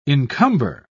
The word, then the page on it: incumber: British English